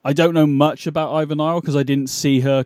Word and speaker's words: Ivy Nile: She was defending the championship against Ivy Nile. I believe I'm saying that right. – Yeah, uh, she was. – No, you you were saying it pr- uh, to perfection. I couldn't have said it better.